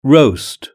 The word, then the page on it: roast: British English